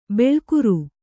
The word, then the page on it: ಬೊಳ್ಳುಗರೆ: Kannada